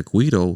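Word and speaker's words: güiro: And then the other instructor, who is the director of the rondalla, is playing what is known as the guido. Is that how you say it, right? – Yeah, that's the way. – And it's a guido,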